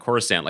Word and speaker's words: Coruscant: But, like, I distinctly remember being an obsessive child fan of Star Wars and the prequels coming out and everyone just tossing around Coruscant like they knew it was a soft C.